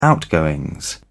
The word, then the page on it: outgoings: British English